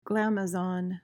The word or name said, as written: glamazon